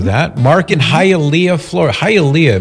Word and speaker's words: Hialeah: Hialeah. So if you know a girl named Leah, you'd say, Hialeah.